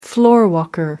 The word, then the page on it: floorwalker: American English